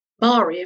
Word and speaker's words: Marie: Mari, of course, rhymes with starry, and Lloyd was already familiar to the public, being on matchboxes, soap, newspapers, and various other consumer products.